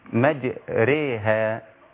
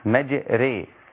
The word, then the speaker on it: مجراها: مجريها. – نعم. – تمام. – نعم. – مجري ليس ري ولا را.